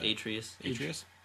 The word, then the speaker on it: Atreus: Is that Adaris? How do you pronounce that? – Atreus.